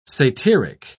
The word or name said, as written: satyric